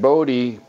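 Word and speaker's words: Bote: Batting eighth, David Bodie, Bodie McBoatface, is playing second base. – Rhymes with Grody.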